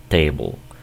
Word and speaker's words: table: Cuando tengas una palabra de este tipo, esta E que está aquí al final no se va a pronunciar, ¿ok? Nunca se va a pronunciar. Ese es el primer principio que debes saber. Aquí esta palabra se pronuncia else y acá se pronuncia table.